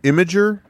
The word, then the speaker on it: Imgur: No, wait, how is that pronounced? Imgur? – Yes. – Okay, because to me it looks like Imgur.